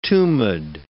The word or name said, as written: tumid